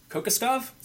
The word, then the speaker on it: Kokoskov: I might butcher this name, but Kokoskov, is that right? – Yeah, I'd go with that.